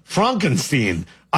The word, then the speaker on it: Frankenstein: Frankenstein, I believe, is how we pronounce that.